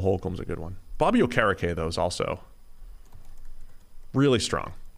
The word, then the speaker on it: Okereke: Bobby Okereke. – I forgot he changed his pronunciation. – Yeah. It's not Okereke. – Not anymore.